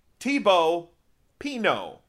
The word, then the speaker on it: Thibaut Pinot: Thibaut Pinot.